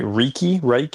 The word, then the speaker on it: Reiki: So it's how like, do you I see you do, I don't know if I pronounce this word right, Reiki.